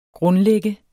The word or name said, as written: grundlægger